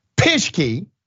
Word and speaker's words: Pischke: Pischke.